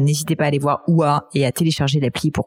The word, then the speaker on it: Oohaah: Alors, ça s'écrit OUA. Ça se prononce OUA et ça s'écrit O-O-H-A-A-H.